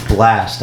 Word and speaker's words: Blxst: It's Blast. Have you ever heard of him? – No. – It's spelled BLXST.